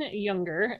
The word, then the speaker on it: Junger: It's by Sebastian Junger. And I may have mispronounced his last name because it's spelled with a J, but I'm assuming it's pronounced with a Y.